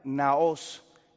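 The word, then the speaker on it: naos (ναός): Así como usted lo encuentra pronunciado, naos, N-A-O-S.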